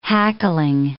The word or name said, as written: hackling